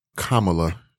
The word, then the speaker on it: Kamala: And up and down my timeline yesterday, I saw tons of checks and pearls with, you know, young black women embracing, and I've been saying her name wrong forever. Yes, Kamala. – Kamala. – Kamala is how you pronounce her name.